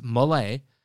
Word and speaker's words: Milei: If you have heard people pronounce it any other way, it's wrong. It's Milei.